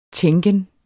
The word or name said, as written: tænken